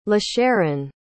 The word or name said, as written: lasharon